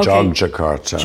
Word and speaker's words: Yogyakarta: I want to go back to the Yogyakarta. How do you say this? – It's actually, it's pronounced like a J, okay. Yogyakarta.